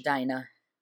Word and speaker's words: Dinah: Do you pronounce it Dinah or Dinah in English? – Dinah. Yeah.